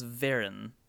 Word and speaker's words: Fearn: Celtic pronunciation is hard, and while this name is spelled F-E-A-R-N, most, but not all, of the guides I read insisted it was pronounced with a V, so this is me trying my best.